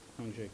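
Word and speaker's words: Hongzhi: It's H-O-N-G-Z-H-I. And it's kind of pronounced zh. Sure. – What is it? – Hong zh.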